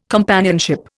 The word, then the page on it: companionship: Indian